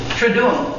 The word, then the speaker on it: Triduum: Triduum. Some will say, the U's are both pronounced.